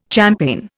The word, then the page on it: champaign: Indian